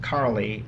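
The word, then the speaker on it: Károli: By the way, our school, Crowley Gasper, they pronounce it Carly.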